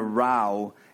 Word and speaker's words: row: I like the part, the remonstrance of my friends terminated in a row, and I became a lone wolf. Terminated in a row is the way you pronounce that.